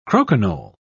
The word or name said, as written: croquignole